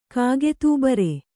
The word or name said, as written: ಕಾಗೆತೂಬರೆ